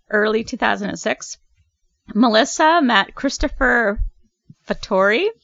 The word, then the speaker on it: Fattori: It's like F-A-T-T-O-R-I? – E. – Oh, Fattori. – Fattori. – It's an A sound, like it's Italian, so Italian. – They met online. – Fattori.